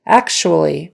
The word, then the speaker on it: actually: Actually. It's spelled with a C-T-U in the middle there, but you pronounce it, that T-U, like a S-H.